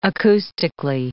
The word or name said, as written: acoustically